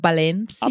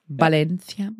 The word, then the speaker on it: Valencia: wird ja wie B ausgesprochen, eine Valencia.